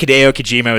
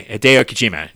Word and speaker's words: Hideo Kojima: I don't think Hideo Kojima is that... sorry, I mispronounced that, didn't I? Hideo Kojima.